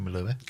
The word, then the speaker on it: Melua: Katie Melua, I'll spell it M-E-L-U-A. – Katie Melua, singer. – Singer, yeah. I've never heard of it. – 38. – 50. – Is Katie Melua or Katie Melua? – Melua.